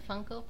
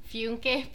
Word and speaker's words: Funko: Is that how you pronounce it? Funko Pop. – Yeah. – Okay, I've been calling it like Funke Pop, like Tobias Funke from Arrested Development.